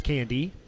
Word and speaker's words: Cande: By the way, Candy's last name is spelled C-A-N-D-E, not with a Y at the end, spelled with an E at the end. Nonetheless, it's pronounced Candy.